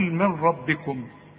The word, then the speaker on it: من ربكم: يبقى علشان اسهل لك الموضوع اقول لك ما تنطقش النون دي الكامل عند الايه عند الوصل، اسقطها من النطق، تثبت عند الوقت تقول بلاء من، انما هو ده مش وقت، بس علشان تعرف انك اذا وقفت عليها لاي سبب واخد بالك، انما عند الوصل اسقطها، اشبك الميم مع الراء، قل من ربكم. وهكذا في عموم القرآن، نون سكنة بعدها ره تسقطها من النطق، اظن سهلة.